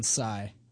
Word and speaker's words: Psy: But I hate how people are like, oh, they call him Psy like they know him. Dude. – It's supposed to be the end of the world. – It's a silent P.